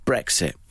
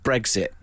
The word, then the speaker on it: Brexit: I pronounce it how it should be. Brexit. – Right. – But there are people that call it Brexit. And that eggs. Really, really irritates me.